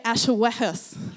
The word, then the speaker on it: Ahasuerus: Everybody say Ahasuerus.